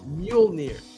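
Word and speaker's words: Mjölnir: I don't know what those two dots are, but it's pronounced like Mjolnir.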